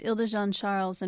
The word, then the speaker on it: Île de Jean-Charles: Yeah, it's Île de Jean-Charles and Pointe-à-Chenne are the two places. And I think I still butcher some of the pronunciations in the area, but I think I have those two right because they're French.